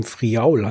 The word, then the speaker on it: Friaul: 3 Flaschen Grauburgunder, einen sehr, sehr leichten vom Gardasee, einen und 2 aus dem Friaul, heißt das, glaube ich. So spricht man das tatsächlich auch aus.